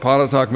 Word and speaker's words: Pontotoc: And it's a small town, Pontotoc, Texas is its name. Some people have asked me to spell it. I will do that. I don't have a blackboard. P-O-N-T-O-T-O-C.